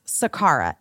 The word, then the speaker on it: Sakara: That's Sakara.